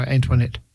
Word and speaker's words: Antoinette: Well, it's spelled Antoinette, but yeah, probably is French, so Antoinette.